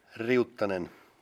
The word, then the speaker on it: Riuttanen: Riuttanen.